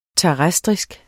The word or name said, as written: terrestrisk